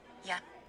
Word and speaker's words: jeg: Yeah, and it's not J-A. – Yeah. – Yeah is J-E-G. – Yeah. Yeah. Yeah. – What do I hear? – Yeah. – J-E-G.